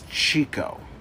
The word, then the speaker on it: Chikko: Okay, so the guy's name is Chico, right? But it's spelled C-H-I-K-K-O.